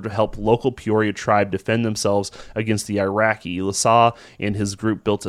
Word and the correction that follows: Iroquois: I think that was an IROQ. How do you spell that? Is that the Iroquois? – Iroquois. What did I say? Iraqi? – Iraqi, yeah. Yeah.